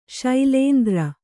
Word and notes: ಶೈಲೇಂದ್ರ: śailēndra

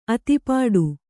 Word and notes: ಅಧಿಪಟ್ಟ: kannada

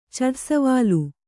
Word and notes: ಛಡಸವಾಲು: chaḍa savālu

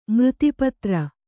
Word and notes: ಮೃತ್ಪಾತ್ರ: mřtpātra

